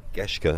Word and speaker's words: Geschke: Is that the right pronounce? – Geschke, apologies.